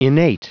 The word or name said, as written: innate